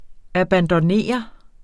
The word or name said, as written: abandonnere